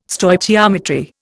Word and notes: Stoichiometry: Indian

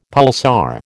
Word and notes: pulsar: American English

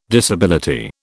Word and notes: disability: British English

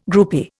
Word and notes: groupie: Indian

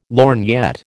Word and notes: lorgnette: American English

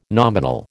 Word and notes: nominal: American English

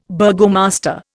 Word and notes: burgomaster: Indian

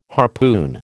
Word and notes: harpoon: American English

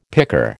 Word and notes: picker: American English

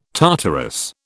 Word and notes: Tartarus: British English

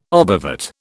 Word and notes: obovate: British English

